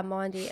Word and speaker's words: Maundy: On Thursday, I went to, how do you say it, Maundy? – Maundy Thursday? – Yeah, Maundy.